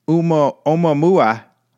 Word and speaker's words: Oumuamua: And essentially, this is the guy, the scientist, who suggested that this planetary object, Oumuamua, that's how you say it, I think it's a really fun name to say.